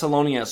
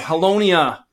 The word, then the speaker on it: Holownia: And I am Travis Holownia.